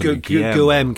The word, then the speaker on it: Guillaume: Or is it Guillaume? – Yes, Guillaume, because he's French.